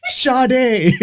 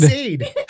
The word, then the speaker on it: Sade: That's not how you pronounce Sade. – How did you pronounce it? – I called it Sade.